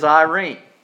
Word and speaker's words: eirene: Now, when you look at verse 14 where it says on earth peace, I'm going to give you that Greek word again, E-I-R-E-N-E. And I discovered in studying it this week, the contemporary Greek pronunciation is Irene. But the pronunciation in the Greek probably 2 years ago was Irene. Irene. But anyway, it's E-I-R-E-N-E.